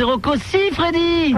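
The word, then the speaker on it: Rocco Siffredi: Rocco-Sifondi. – Eh non, c'est Rocco-Sifredi. – Freddy, oh ben, j'ai mal prononcé.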